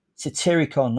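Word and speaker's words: Satyricon: Satiricon, that's it.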